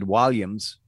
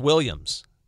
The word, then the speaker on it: Walliams: It was popular enough that they had a remake of it with David Walliams, who some people will remember. I believe it's pronounced Williams. – No, Walliams.